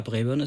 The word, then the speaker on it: Braeburn: Ja, Braeburn ist schon richtig ausgesprochen.